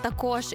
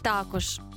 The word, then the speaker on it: також: Слово «також» і «також». Частка, яку ми вживаємо щодня, вона однаково правильно буде звучати, як ти скажеш, чи також, чи ти скажеш також.